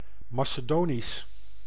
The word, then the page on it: macédonien: [ma.se.dɔ.njɛ̃]